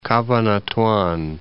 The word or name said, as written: Cabanatuan